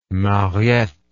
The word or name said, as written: Mariette